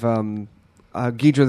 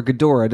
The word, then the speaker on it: Ghidorah: Monster Zero, where, you have Ghidra the Three-Headed Monster, or Ghidorah, depending on how you like to pronounce it, showed up, so that was cool.